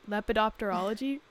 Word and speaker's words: lepidopterology: lepidopterology butterflies and moths